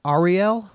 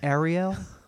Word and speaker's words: Ariel: Is that right? Ariel? What did I say? Ariel? Ariel. Like Ari Gold from Entourage? Ari Gold had a daughter, Ariel.